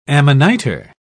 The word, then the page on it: amanita: British English